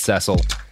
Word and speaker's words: Cecil: I know it's Cecil.